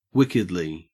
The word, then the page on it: wickedly: Australian English